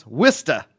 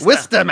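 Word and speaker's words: Worcester: This is from Jim from he says pronounce Wista. – Wista. – Wista, Massachusetts.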